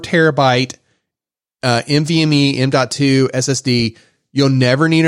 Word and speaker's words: NVMe: Joe's not going to care, but Alan, 4 terabyte NVMe 2. Wait, did I say that right? – NVMe. Yep.